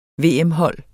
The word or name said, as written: VM-hold